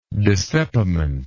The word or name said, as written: dissepimental